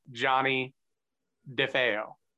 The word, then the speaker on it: Johnny Defeo: My name, pronounced correctly, is Johnny Defeo.